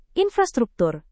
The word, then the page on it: infrastruktur: indonesian